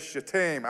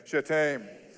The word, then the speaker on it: Shittim: Now, the way you pronounce that word is Shittim. I want you to say that with me, Shittim. I don't want a private reading of the Bible to ever take the wrong direction with this word, Shittim. Literally how you say it in the Hebrew, Shittim.